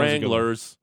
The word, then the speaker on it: Wranglers: There were some kids in the crowd who were going, Wranglers, and it was driving me nuts. It's not three syllables. – Okay, it's two.